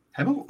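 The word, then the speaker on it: Hemel: Is that how pom say it? Do they say Himmel or is it like Hummel or is it a silent H or whatever the hell they do? – Himmel, oh, yeah, I mean Himmel Hempstead,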